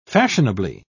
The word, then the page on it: fashionably: British English